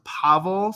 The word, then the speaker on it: Pavel: Yeah, so it is pronounced Pavel, so P-A is the emphasis.